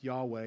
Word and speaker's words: Yahweh: It's pronounced Yahweh if you have to pronounce it.